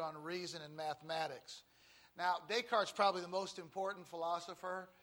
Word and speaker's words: Descartes: That's Descartes. Not pronounced Descartes. But Descartes, okay? You don't want to look like a fool when you pronounce his name. Descartes. He's a Frenchman. Usually in pronouncing French words, you just skip the last section. It's usually always silent.